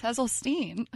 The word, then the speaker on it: Hesseltine: That's not how you say my name. – I know. It's a joke. Hesseltine. – It's Hesseltine.